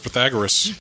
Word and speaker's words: Pythagoras: Answer is Pythagoras.